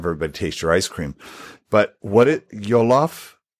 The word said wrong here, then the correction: jollof: did I say it right? Yolof rice? – Jollof rice? – Yeah.